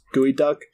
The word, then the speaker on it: Geoduck: It's spelled G-E-O-D-U-C-K. Like, it looks like Geoduck, but it's pronounced Gooey Duck.